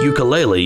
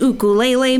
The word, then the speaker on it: ukulele: Oh, that's a nice ukulele you have there. – Oh, it's pronounced ukulele. Ukulele.